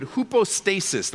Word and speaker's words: hypostasis: You can circle that word and you can write next to it, hypostasis.